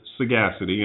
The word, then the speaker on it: Sagacity: And her page is Conversations with Sagacity, and that's all one word. Sagacity is spelled S-A-G-A-C-I-T-Y. It's kind of like Saga City or Sega City, S-A-G-A City, all one word, Conversations with Sagacity.